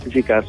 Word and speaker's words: Zikar: Il titolo sarebbe Zikar, ho detto bene? – Zikar, sì.